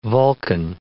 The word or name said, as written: Vulcan